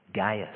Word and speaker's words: Gaius: He's a man called Gaius. Or Gaius, depending on your pronunciation.